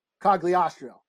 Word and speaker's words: Cogliostro: Cogliostro, or what's his name? – You said it right.